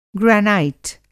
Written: ग्रेनाइट

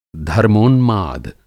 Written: धर्मोन्माद

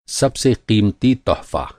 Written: तोहफ़ा

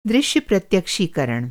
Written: प्रत्यक्षीकरण

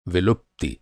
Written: विलुप्ति

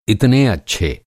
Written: इतने

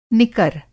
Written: निकर

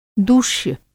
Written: दूष्य

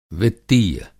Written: वित्तीय

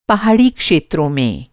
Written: पहाड़ी

